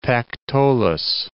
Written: Pactolus

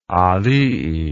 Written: aalii

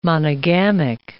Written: monogamic

